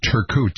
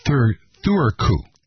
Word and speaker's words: Turku: It's not Turku, T-U-R-K-U, but it's Thurku.